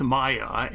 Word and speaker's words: Maija: Then we begin with Maya, and you spell her name as M-A-I-J-A, but she pronounces it like M-A-Y-A.